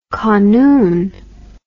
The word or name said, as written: kanoon